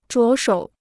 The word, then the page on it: 着手: zhuó shǒu